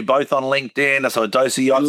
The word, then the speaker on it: Idosy: Idosi. Yeah, so, I mean, we're not fussy about how people pronounce it. You know, Idosi, I-D-O-S-Y.